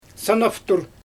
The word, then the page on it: sanaftur: Xyzyl